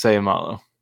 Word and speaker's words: Seumalo: In fact, I might even, is Isaac Seumalo? Seumalo? – Seumalo? – Seumalo? – I think it's Seumalo. – Seumalo? There we go.